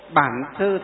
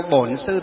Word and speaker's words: Bản Sư: Nam Mô Bản Sư Thích Ca Mâu Ni Phật, là tiếng miền Bắc. Miền Nam đọc là Nam Mô Bổn Sư Thích Ca Mâu Ni Phật, là tiếng miền Nam.